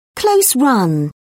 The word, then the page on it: close run: British English